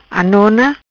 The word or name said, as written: Anona